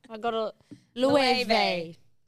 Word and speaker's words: Loewe: How do you go about pronouncing this name? It's not Loewe, but rather Loewe.